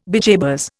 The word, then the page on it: bejabers: Indian